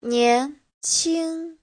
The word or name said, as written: nian2qing1